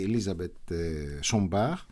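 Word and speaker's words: Élisabeth Sombart: Είναι η Ελίζαμπετ Σομπάρ, στη γαλλική προφορά του ονόματος, γιατί είναι από το Στρασβούργο, η καταγωγή της είναι γερμανική, η οικογένειά της μάλλον.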